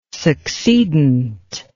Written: succedent

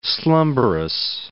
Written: slumbrous

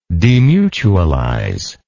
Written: demutualize

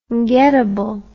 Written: getable

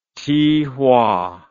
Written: Tihua

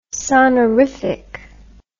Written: sonorific

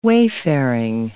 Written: wayfaring

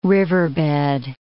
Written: riverbed

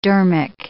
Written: dharmic

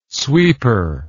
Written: sweeper

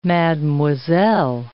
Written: mademoiselle

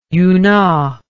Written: unau

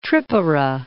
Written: Tripura

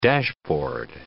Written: dashboard

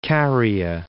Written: Caria

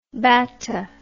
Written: batta

